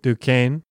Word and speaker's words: Duquesne: Ah, ¿cómo se pronuncia? – Fort Duquesne. – ¿En serio? ¿Se pronuncia así? – Sí.